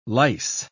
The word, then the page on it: lyse: British English